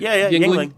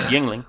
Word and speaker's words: Yuengling: Did I say that right? I'm always a little confused. – Yingling. Yeah. I mean, it's spelled like Yuengling, but it's Yingling.